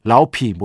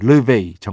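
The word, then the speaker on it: Laufey: 예전에는 우리가 라오피 뭐 이렇게 불렀죠. 아이슬란드 뮤지션이고요. 비슷한 발음은 르베이 정도 됩니다.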